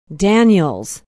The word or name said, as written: Daniels